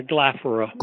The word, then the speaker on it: Glaphyra: I'd like to move now to the next figure you focus on, and if I if I mispronounce the name, please correct me. It's Glaphyra of Cappadocia? – Yeah, Glaphyra. – Glaphyra, excuse me. – Would be better, yeah.